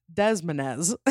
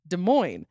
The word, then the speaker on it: Des Moines: I read it and I pronounced it as Des Moines. Everyone started asking what Des Moines was. So I showed them the card and was quickly told it's pronounced Des Moines. All this time I had been pronouncing it wrong and I was the laughingstock at the New Year's Eve party.